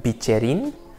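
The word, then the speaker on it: bicerin: It's called a bicchierin. I think it's the proper pronunciation for it. It's B-I-C-E-R-I-N.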